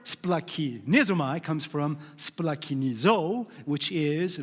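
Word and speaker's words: splakinizomai (σπλαγχνίζομαι): It is pronounced splakinizomai.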